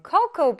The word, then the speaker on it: cocoa: 근데 선생님이 이 단어 읽을 때 코코아라고 안 읽고 어떻게 읽었어요? Cocoa beans. 맞아요. 코코우라고 발음이 됩니다.